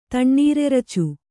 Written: ತಣ್ಣೀರೆರಚು

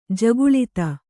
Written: ಜಗುಳಿತ

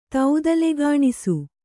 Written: ತೌದಲೆಗಾಣಿಸು

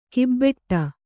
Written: ಕೊಬ್ಬಟ್ಟು

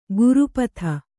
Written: ಕುರೂಪತೆ

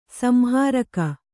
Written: ಸಂಹಾರಕ

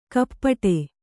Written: ಕಪ್ಪಟೆ

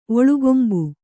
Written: ಒಳ್ಗೊಂಬು

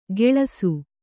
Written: ಗೆಳಸು

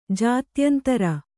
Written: ಜಾತ್ಯಂತರ

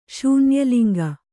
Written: ಶೂನ್ಯಲಿಂಗ

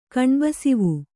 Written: ಕಣ್ಬಸಿವು

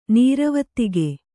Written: ನೀರವತ್ತಿಗೆ

